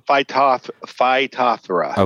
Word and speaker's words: Phytophthora: Phytophthora.